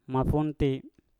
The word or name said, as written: mafunti